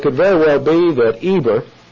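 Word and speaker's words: Eber (עֵבֶר): Pronounced E-B-E-R, Eber.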